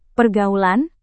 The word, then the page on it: pergaulan: Indonesian